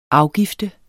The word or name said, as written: afgift